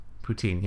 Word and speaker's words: poutine: Poutine? – Poutine, yeah.